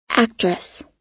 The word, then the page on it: actress: American English